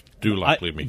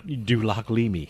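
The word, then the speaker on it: Lac-Leamy: Surprisingly, this is pronounced just like it's spelled. – Dulac-Leamy.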